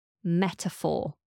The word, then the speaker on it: metaphor: And this is the word metaphor. Metaphor. We spell it M-E-T-A-P-H-O-R. Metaphor. Paying attention to how we pronounce the PH is often as an F sound, metaphor.